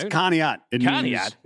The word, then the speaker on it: Conneaut: How do you say that, Kanuit? – It's Conneaut. It means snowy waters. – Oh, because you're from Ohio. – That's in my neck of the woods, where I grew up. Yeah, that's Conneaut. Like when people try to pronounce like Worcester.